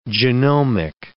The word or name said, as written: genomic